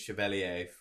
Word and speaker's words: Chevalier: I think from Donnarumma's perspective, PSG have obviously signed Lucas Chevalier from Lille. Mm-hmm. Do you appreciate the pronunciation? – That was very good.